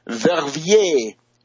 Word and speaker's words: Verviers: It is continuing in the sense the turmoil and the agitation in France, Germany, Netherlands, and Belgium, in particular the town of Verviers, V-E-R-V-I-E-R-S, Verviers.